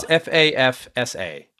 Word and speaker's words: FAFSA: Is it FAFSA or FAFSA? – It's FAFSA.